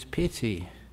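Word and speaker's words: pīti: And when the enlightenment factor of joy is present in you, now this is pity. Now, pity is, P long I-T-I.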